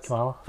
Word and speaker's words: Kamala Khan: Kamala Khan, that's how you say it, right? – Kamala, yeah.